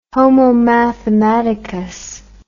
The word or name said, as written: homo mathematicus